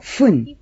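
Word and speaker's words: fun (ふん): Básicamente, minuto se dice fun, pero en algunos casos el sonido de la F en fun cambia a P y queda pun.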